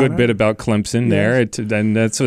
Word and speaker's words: Clemson: there's a P in Clemson. You don't see it. You don't spell it that way, but you'll get it right if you just spell it C-L-E-M-P-S-I-N, Clemson. Correct. That is the correct way to pronounce it. A lot of people want to put a Z in there. They want to say Clemson. No, not like crimson.